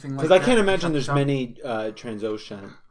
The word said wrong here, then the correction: Trandoshan: How do you say it again? – Trandoshan. Perfect.